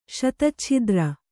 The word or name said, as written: ಶತಚ್ಛಿದ್ರ